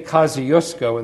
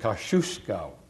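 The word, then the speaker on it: Kościuszko: And this was Thaddeus, well, we say Kosciuszko in this country, but the Poles I now know pronounce it Kosciuszko. So I'll call it Kosciuszko.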